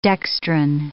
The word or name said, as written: dextrin